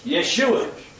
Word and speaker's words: Yeshua: In the Hebrew, it's pronounced Yeshua. See, Greek doesn't have a Y, so that's for a J. Yeah? So it's Yeshua in Hebrew.